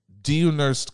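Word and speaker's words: Dieunerst: Deonerst Collin. – Deonerst Collins. Jesus. – How do you spell that? – D-I-E-U-N-E.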